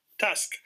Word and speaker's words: TUSC: And again, like, how am I pronouncing this? Is it TUSC? Is it TUSC? – TUSC. So our mascot's an elephant. – Oh, so, okay. – But we wanted to do TUSC, which is kind of a play on the elephant.